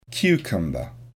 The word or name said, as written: cucumber